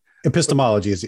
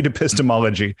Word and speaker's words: epistemology: I never say this right. – Oh, yeah. Epistemology. – Epistemology. Yeah, right.